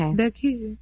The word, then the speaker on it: Baucoop: Now spell that for us. – B-A-U-C-O-O-P. – Okay. – Like the French word. – Okay. – Boku. Like merci beaucoup.